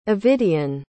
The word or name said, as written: ovidian